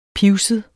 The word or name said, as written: pivset